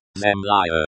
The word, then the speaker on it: Земля: It is pronounced, Zemlya.